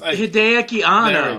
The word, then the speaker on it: Hideaki Anno: Directed by the original director and creator of the series, Hideko Kano, I believe how it's pronounced. – Hideaki Anno.